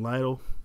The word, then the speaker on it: Lytle: You can follow me online at Julian Lytle. My last name is spelled L-Y-T-L-E.